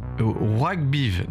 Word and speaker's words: ROYGBIV: Et le morceau parfait pour ça, alors là, la prononciation, c'est Rockbiv, donc R-O-Y-G-B-I-V.